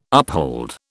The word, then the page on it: uphold: British English